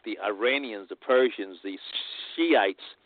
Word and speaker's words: Shiites: There are several, but the two biggest are the Sunni and the Shiites, spelled S-H-I-I-T-E-S, I think it is. It's not what you want to pronounce it. It's the Shiites.